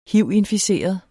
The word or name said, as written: hiv